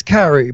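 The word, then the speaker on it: Kerrie: But it's Carrie, but he has this weird Florida orange accent.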